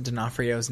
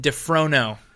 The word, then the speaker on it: D'Onofrio: He, for the longest time, and I am a monster because I let him do it, he mispronounced Vincent D'Onofrio's name. Forever. – Really? – What did I call him? – You called him Vincent DeFrono.